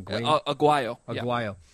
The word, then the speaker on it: Aguayo: Well, we've gone this whole hour without talking about Roberto Aguayo. Did I say that right? Aguayo. Aguayo.